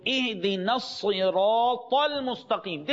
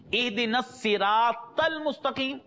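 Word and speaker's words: اهدنا الصراط المستقيم: So اِهْدِنَا الصِّرَاطَ الْمُسْتَقِيمِ. This is the correct pronunciation. اِهْدِنَا الصِّرَاطَ الْمُسْتَقِيمِ is incorrect.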